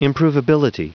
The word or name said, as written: improvability